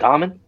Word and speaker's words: Dahmen: But I don't think you say the H. – Dahmen. – Dahmen? – Damon.